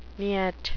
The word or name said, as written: nyet